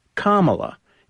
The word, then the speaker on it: Kamala: Harris's first name is pronounced Kamala. It's derived from ancient Sanskrit and is a nod to her Indian heritage.